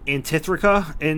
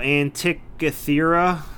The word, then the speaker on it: Antikythera: I don't think so, but can you try saying the word that they called the dial? I can't pronounce it. – Antithrica? No, Antikythera? Something like that, right? – Yeah, that sounds... – Antikythera?